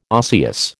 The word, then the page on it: osseous: American English